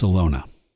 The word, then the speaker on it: Alona: Her name was Alona, with a long O, to rhyme with Ramona.